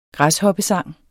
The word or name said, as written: græshoppesang